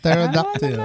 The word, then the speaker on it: pterodactyl: Because the P is silent. Tama naman, pterodactyl.